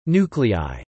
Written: nuclei

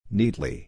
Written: neatly

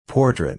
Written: portrait

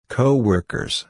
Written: coworkers

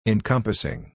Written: encompassing

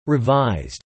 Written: revised

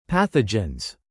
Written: pathogens

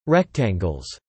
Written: rectangles

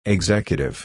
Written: executive

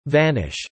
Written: vanish

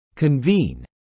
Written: convene

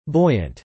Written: buoyant

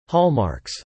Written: hallmarks

